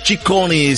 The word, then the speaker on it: Ciccone: La bambola italiana, elle s'appelle Ciccone, c'est en trois syllabes, Madonna en trois syllabes, elle révolutionna le monde du 45 tours, rappelez-vous, au tout début des années 80 avec Holiday.